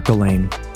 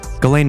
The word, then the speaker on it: Ghislaine: I'm going to call her Ghislaine. Ghislaine Maxwell. Silent S.